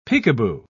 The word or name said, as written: peekaboo